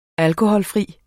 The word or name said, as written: alkoholfri